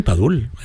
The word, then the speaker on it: Padul: Sí, sí, Padul, Padul, Granada.